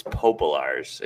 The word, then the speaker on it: Popolars: Yeah, we skipped the practice part, but it's Popolars. It's a tough one, you know. It's a long one. It doesn't really get pronounced exactly how it looks, so it's okay.